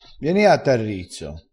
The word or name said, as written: vieni